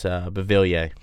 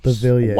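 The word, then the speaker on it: Beauvillier: Like, how do you say it, Bellevier? – No, it's Bevilier. – Bevilier.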